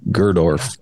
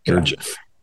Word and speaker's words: Gurdjieff: and your reference to Cynthia and Gerdorf, or however you say his name. – Gurdjieff.